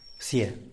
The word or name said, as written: psie